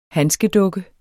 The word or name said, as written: handskedukke